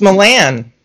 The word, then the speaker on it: Milan: Yeah, Cameron Merlan. – Merlan. That's how I pronounce it. There's no R in it. – Did you forget your R? – It's Milan.